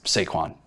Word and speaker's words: Saquon: We're not a pronunciation podcast, although it's Saquon. But so, I was way off, guys.